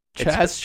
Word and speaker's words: Chazz: And the baby doll of the week is Chaz Chips. – Chaz Chips. – It's spelled, it's a Lithuanian company. It's spelled C-H-A-Z-Z. – Oh, okay? Like jazz. – Like jazz, exactly.